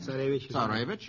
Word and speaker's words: Tsarevich: Tsarevich.